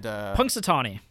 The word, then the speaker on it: Punxsutawney: And Punxsutawney.